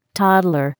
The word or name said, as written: toddler